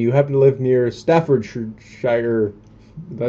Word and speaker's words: Staffordshire: Staffordshire. – Okay, yeah. – Staffordshire.